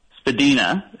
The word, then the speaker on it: Spadina: Spadina is how you say that one.